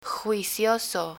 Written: juicioso

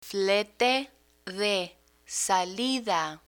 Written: flete de salida

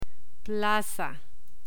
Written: plaza

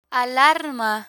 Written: alarma

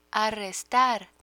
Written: arrestar

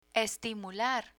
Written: estimular

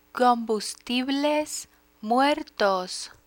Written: combustibles muertos

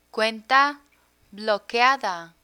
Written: cuenta bloqueada

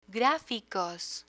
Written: graficos

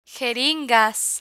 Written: jeringas

